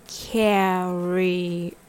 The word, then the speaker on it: carrier: Carrier has three syllables. Carrier. This time the stress is on care. So it goes like this. Carrier. It sounds like ta-da-da.